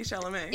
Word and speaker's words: Chalamet: Yes, Timothy Chalamet.